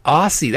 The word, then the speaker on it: Aase: Don, oh, Don Aase. That's how you pronounce that.